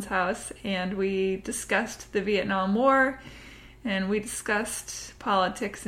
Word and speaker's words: Vietnam: I don't know why I said Vietnam. I don't think that the enunciation is on that syllable, but it's okay, it's late, and I don't care. Vietnam, there, anyway.